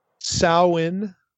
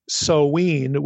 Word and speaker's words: Samhain: But according to a linguistic website that I found on the NC State website, the larger website, this term is pronounced either Samhain, like ow, the O-W in cow, or Samhain with the O-W like glow.